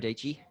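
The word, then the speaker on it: Dietschy: Sarah Deechy. – Rhymes with peachy? – Yep.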